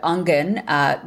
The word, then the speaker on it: Aangen: And as you know, a lot of the work that we do at Ungen, which is spelled A-A-N-G-E-N, just for people who want to have that spelled out, we focus a lot on food security and we create jobs.